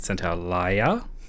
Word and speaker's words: Santaolalla: We consistently mispronounce this man's name, and I have finally learnt loosely how to pronounce it. So he lost to the composer Gustavo Santaolalla, or if you're more Castilian, Santaolalla, but given that he's Argentinian, Santaolalla, who in this case had written his score for Babel.